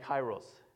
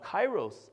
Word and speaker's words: kairos: This is not the way the Greeks would say it, but this is how we Americans say it, chronos and kairos.